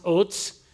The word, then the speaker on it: Uz: It is a Hebrew word that is actually pronounced Otz,